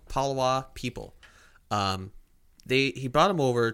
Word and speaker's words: Palawa: Palawa. Yes, I pronounced that right. Palawa people. P-A-L-A-W-A.